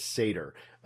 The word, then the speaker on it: Seidr: Um, the actual pronunciation of the title is Seder.